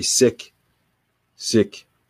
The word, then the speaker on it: Sikh: I like to, I like to give value in my life, but when I think of the Sikh people, Sikh, I think it's sick. I need to I need to say it right. Sick, sick.